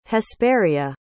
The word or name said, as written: hesperia